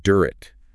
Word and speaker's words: Durrett: That's D-U-R-R-E-T-T. – Yes, Durrett. And just a heads up, we'll be saying his name a lot, and it's pronounced Durrett, not Durrett.